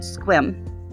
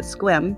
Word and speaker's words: Sequim: It's actually in this small town called Squim. It's spelled Sequim, but you, you pronounce it Squim.